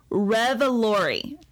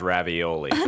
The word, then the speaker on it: Revolori: we have Tony Revolori. – Revolori. pronounced ravioli. – no, that's not it.